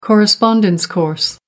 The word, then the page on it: correspondence course: American English